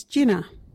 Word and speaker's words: jina: Jinnah is spelt J-I-N-A. Can you say jinnah?